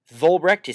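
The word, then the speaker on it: Vollbrecht: Volbrecht is spelled V as in Victor,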